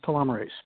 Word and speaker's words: telomerase: So is it telomerase? Is that how you pronounce it? – The most common pronunciation is telomerase.